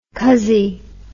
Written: cuzzy

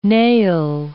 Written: nail

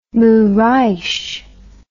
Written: Moraes

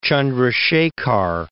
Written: Chandrasekhar

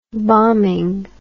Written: bombing

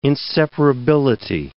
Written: inseparability

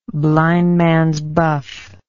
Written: blindmans buff

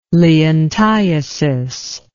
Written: leontiasis

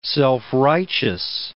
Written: self-righteous

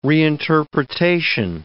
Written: reinterpretation

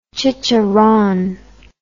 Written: chicharron

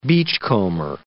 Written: beachcomber

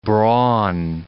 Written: brawn

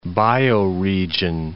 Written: bioregion

